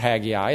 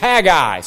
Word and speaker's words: Haggai: And afterwards, he laughed at me and was telling me how I was pronouncing it, Haggai. And then I looked at it and I thought, no, it's not Haggai, it's Haggai.